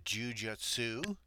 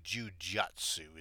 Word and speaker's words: jujutsu: Now my Google foo tells me that jujitsu, it's actually jujutsu is the proper spelling and pronunciation,